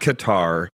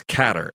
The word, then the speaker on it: Qatar: By the way, you mentioned Qatar, so let's get it out of the bag now. It's not Qatar, it's Qatar.